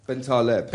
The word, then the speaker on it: Bentaleb: How do we pronounce By the way, just earlier, my Algerian hairdresser tells me it's Bentaleb. – Bentaleb? – Bentaleb.